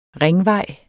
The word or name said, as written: ringvej